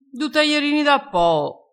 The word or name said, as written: taierino